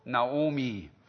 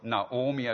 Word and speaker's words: Naomi: Der hieß Elimelech und seine Frau Naomi oder Noomi oder Noemi. Es gibt verschiedene Aussprachen dafür. Ich werde mich auf Naomi als die bekannteste ähm, äh, Pronunciation aus, äh, einigen.